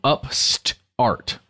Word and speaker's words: Upstart: So UPST. Art, right? But it's Upstart. Oh, that's how you would say it.